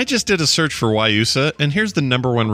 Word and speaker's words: Guayusa: It's pronounced Guayusa. G-U-A-Y-U-S-A.